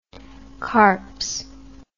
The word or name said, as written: carps